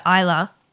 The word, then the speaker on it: Islay: Yeah, and then Campbelltown is very, very similar to Islay.